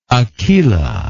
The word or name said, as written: Akela